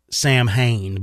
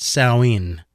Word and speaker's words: Samhain: Now, what they found is they believe it dates back, the origins of Halloween, to an ancient Celtic festival of, now I always pronounced it Samhain, but I believe it's pronounced Samhain. Is what it's pronounced as.